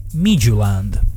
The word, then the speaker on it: Midtjylland: Midtjylland. – Questa la pronuncia corretta, no, ho capito, ragazzi.